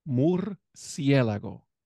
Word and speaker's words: murciélago: The correct way to pronunciate bat in Spanish is murciélago. – Murciélago.